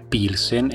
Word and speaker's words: Pilsen: la cui pronuncia tedesca è Pilsen e da lì il nome definitivo dello stile.